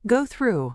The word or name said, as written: go through